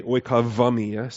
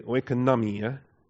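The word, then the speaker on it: oikonomia (οἰκονομία): But don't say oikavamia, say oikonomia. Put the accent on the ia, and you're good.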